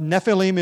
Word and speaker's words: Nephilim: But Nephilim is the Hebrew word transliterated. Just take the Hebrew letters and put them into English letters so we can pronounce it basically the way the Hebrews would.